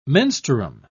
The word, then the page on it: menstruum: British English